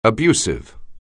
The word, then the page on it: abusive: anglais